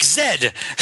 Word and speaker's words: Zed: Wait, although I do have to say that World War Z, Z, like Zed, the English pronunciation of the letter Z. And Zed is like, it rhymes with bed, which is where people sleep.